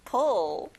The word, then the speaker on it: pull: 당기다. Pull. 뒤에 을 소리 잊지 마세요.